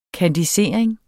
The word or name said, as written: kandisering